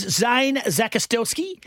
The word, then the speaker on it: Zakostelsky: His name is Zane Zakostelski. – That sounds right.